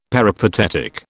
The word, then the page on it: peripatetic: British English